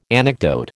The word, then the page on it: anecdote: American English